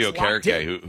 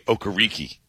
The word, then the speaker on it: Okereke: His first half of his career, he was Bobby Okereke. And then he decided, it was not pronounced that way. He called himself Okereke, and then it was going to be Okereke.